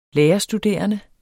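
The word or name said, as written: lærerstuderende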